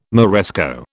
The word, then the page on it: Moresco: British English